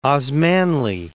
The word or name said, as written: Osmanli